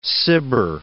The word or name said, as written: Cibber